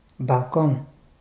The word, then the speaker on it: балкон: Казваш балкон. Французка дума. Къде е ударението? На последната сричка.